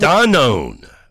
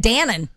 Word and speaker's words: Danone: Danone. That's the way it's pronounced. – I was always saying Dannon.